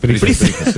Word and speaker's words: Price: ¿El teatro, circo, se pronuncia Price o Price? – Price. – Pues eso, te lo quería preguntar, sí, por si se dice Price. Efectivamente. – Sería precio en inglés, the price.